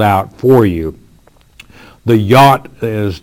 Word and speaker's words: yod: If you have your Bible, you'll have that J-O-D, which is pronounced yot in the Hebrew.